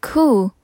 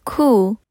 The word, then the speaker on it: coup: 再来第三个 cool C-O-U-P cool，记得 P 不发音，这个字是指政变的意思，特别是指军事政变。